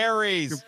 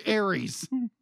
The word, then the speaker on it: Ares: Jared Leto announced that he was playing Arius, a new character. – Ares. – I'm sorry. Ares.